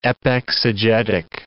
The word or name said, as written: epexegetic